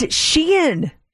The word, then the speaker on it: Shein: Sheen. – It's Shein.